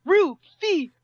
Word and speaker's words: Rufio: Didn't say it right. – So, go ahead. – Rufio! – Oh! – That's better.